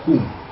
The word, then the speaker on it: хум: На самом деле он, как бы сказать, слог хам, а произносится как слог хум.